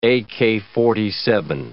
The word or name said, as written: AK-47